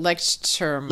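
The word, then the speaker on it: Leuchtturm: I can never pronounce it. Lechterm. – Lechterm. – Yeah.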